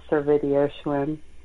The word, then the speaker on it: Servidio-Schwinn: I've listened to you again because you've been on before, and I still butchered that, didn't I? – Yeah, you did. It's Servidio-Schwinn.